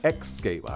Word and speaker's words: Xscape: The one that just faded out, well, that was Xscape. Yeah, Xscape.